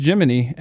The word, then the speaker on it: Gemini: Pronunciation of is pronounced Gemini, as in Gemini cricket.